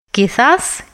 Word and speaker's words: quizás: «Quizás, quizás, quizás». «Может быть, может быть, может быть». В слове «quizás» не забывайте, что буква «сета» произносится как межзубная «с».